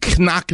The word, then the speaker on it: knock: As my mother used to say, they used to my father, you're a carpenter, you knock nails for a living. She knock is K-N-O-C-K. She would pronounce the K. You knock nails for a living.